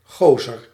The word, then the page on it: homme: France; [ɔm]